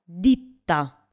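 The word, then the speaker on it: ditta: Хорошо, итальянское слово «дита» означает «пальцы», а слово «дитта» в этом случае, как вы понимаете, в слове две буквы «т» означает «фирма». – «Дита», «дитта».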